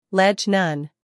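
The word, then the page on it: ledge: uk ledʒ; en-us ledʒ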